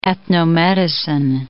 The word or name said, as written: ethnomedicine